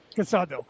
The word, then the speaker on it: Casado: And is it pronounced Casado? Casado? Did I say that right? I apologize. I usually check all the time. – Casado.